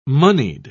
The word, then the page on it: monied: British English